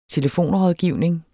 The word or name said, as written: telefonrådgivning